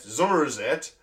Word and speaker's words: Somerset: Or Blobbyland, opened in Cricket St. Thomas, Somerset, Zummerzet, as the locals pronounce it, S's as Z's, in 1994, in the height of Blobby Mania, when the pink, spotty sidekick starred in Noel Edmonds' House Party.